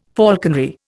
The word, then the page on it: falconry: Indian